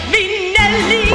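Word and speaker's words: Minnelli: Et I encore deux L, puis le dernier I. Prononcez ainsi Minelli. – Voilà.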